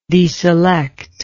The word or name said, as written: deselection